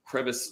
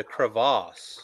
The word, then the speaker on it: crevasse: crevice down below. There's a large cavity beneath this stone slab. – You just didn't say it right. You got to say the crevasse.